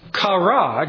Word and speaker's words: chara: But the word kara, joy, has been slightly altered by Paul and turned into the Greek word charis, which is translated grace. Kara, we would spell that C-H-A-R-A in English, is translated into charis, we would spell that C-H-A-R-I-S.